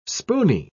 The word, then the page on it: spooney: British English